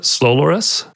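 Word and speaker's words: Slowloris: Is that how you pronounce it? Slolorus? – Mm-hmm. Yeah, effectively a client that opens a connection.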